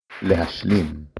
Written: להשלים

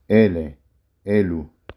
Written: אלה

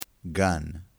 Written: gan

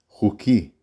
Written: חוקי

